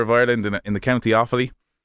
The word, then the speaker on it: Offaly: Tell me if I'm pronouncing this right. Is it Offaly? – Yeah, in Offaly, yeah.